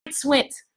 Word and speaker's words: Swint: If you need to get your womb or your womb space together, then you need to check out Goddess Tracy Bryant Swift, right? Let me pronounce her last name correctly. The husband's going to be like, why you always get the Bryant part right, not Swint? Tracy Bryant Swint, all right?